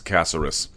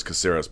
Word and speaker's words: Caceres: The main event, Yair Rodriguez in a featherweight contest taking on Alex Caceres. And everyone says Caceres, but I'm told it's pronounced much more closely to Caceres.